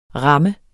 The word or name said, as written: ramme